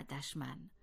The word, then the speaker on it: دشمن: بنابراین می توان نتیجه گرفت که دشنام درست است، نه دشنام، و دشوار درست است، نه دشوار، به همان گونه که دشمن درست است، نه دشمن. پس به خاطر داشته باشیم که حرف دال در کلماتی همچون دشمن، دشوار، دشنام، دشخیم و دشخوی همواره با زمه تلفظ می شود.